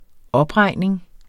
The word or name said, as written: OPREGNING